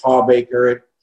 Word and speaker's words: Hawbaker: It's Hawbaker. I tell the girls it rhymes with heartbreaker.